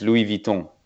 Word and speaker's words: Louis Vuitton: Well, it's actually in French. It's Louis Vuitton.